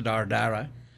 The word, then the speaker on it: Ardara: The little town, you would probably pronounce it Ardara. – Okay. – Spelled A-R-D-A-R-A.